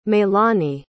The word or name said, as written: meilani